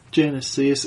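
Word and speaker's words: Genisys: Genesis. It's spelled fucking dumb. – Yeah, it's G-E-N-I-S-Y-S.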